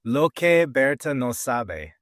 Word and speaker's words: Lo que Berta no sabe: Let me try to pronounce that. Lo que Berta no sabe. – Very good. Yeah, there you go.